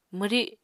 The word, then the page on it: mwri: Apatani